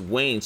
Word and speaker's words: Wane: And my name is Wayne, so that's four letters. W-A-N-E is how I spell it.